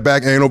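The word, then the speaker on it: Bacchanal: We were trying to hit up that back anal buffet, but didn't get the coupons.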